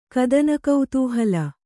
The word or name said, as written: ಕದನಕುತೂಹಲ